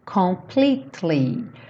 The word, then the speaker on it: completely: Give my heart, it will be completely. Completely. Cuidado para não falar completely. Esse E depois do T não é pronunciado. Então pensa como se fosse T-L-Y.